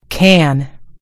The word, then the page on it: can: American English